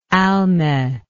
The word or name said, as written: alme